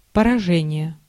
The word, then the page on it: defeat: American English; /dɪˈfiːt/